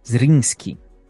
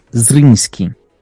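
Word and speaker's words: Zrinjski: O nome do clube é Zrinski. Essa é, é a pronúncia correta. Zrinski.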